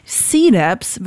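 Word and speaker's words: CDUBZ: C-Labs wird er ausgesprochen. Das C wird einfach ausgesprochen wie das englische C halt. Und dann Dubs wird so ausgesprochen, weil das umgangssprachlich ist für einen anderen Buchstaben, und zwar das W.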